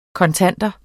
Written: kontanter

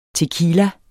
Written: tequila

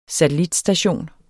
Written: satellitstation

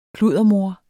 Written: kluddermor

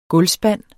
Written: gulvspand

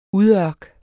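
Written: UDØRKS

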